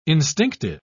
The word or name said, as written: instinctive